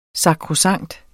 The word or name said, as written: sakrosankt